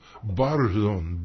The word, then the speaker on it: Barzón: No. The name of El Danzón. – No, Barzón. B-A-R. – Barzón.